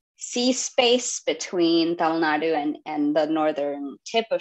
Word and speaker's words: Tamil Nadu: Blueskin Gods takes place in the state of Tamil Nadu. Am I pronouncing that correctly? – Mm-hmm.